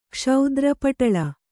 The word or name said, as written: ಕ್ಷೌದ್ರಪಟಳ